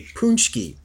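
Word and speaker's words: pączki: Oh, I said it right. A Punschki.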